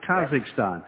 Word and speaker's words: Kazakhstan: Kazakhstan.